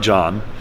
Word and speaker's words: Zhang: So his name is Jile John. So the ZH is pronounced with a J.